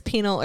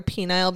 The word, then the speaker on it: penal: I love that you're like, by the way, it doesn't matter how you say it, whether it's penal or penile, because it's people still make fun of it, but it means punishment.